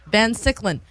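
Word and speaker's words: Van Siclen: Sunday at the, how do you say this, Van Sicklin? Van Sicklin? – Van Sicklin at 4 p.m.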